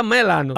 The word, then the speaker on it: Milano: En inglés se pronuncia melano.